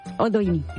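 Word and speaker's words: Oldoini: ¿Oldioni? ¿Lo he dicho bien? – Oldioni. – Ah, perdona. No, está bien que me corrijas.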